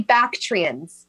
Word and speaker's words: Bactrian: I mean, I know dromedary, but what is it, Bactari? – The Bactrians. – Bactrians.